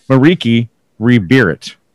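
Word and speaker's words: Merieke Ri Berit: Moving down the list here, number four this week is Mirakai Rebirit. Did I say that right? – Mariki. – Mariki, yeah. – Mariki Rebirit.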